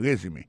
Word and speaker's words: resume: I see from your resume, moi, ouais, dans CV ou dans curriculum vitae, regardez qui j'ai prononcé mon résumé. I see from your resume, accent tombé sur le premier syllabe là.